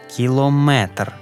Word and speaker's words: кілометр: Міри довжини – міліметр, кілометр, сантиметр, але периметр, барометр, термометр, бо це не міри довжини.